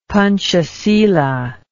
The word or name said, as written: Panchasila